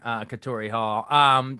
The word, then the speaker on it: Katori Hall: Is it Katori Hall? Is that how you say it? – Correct. – Okay. Yeah. Pulitzer Prize winner, Katori Hall.